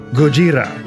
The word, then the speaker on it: Gojira: And on today's show, I'll be insisting on the pronunciation Gojira.